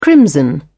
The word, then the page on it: crimson: British English